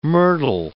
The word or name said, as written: myrtle